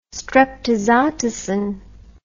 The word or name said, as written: streptozotocin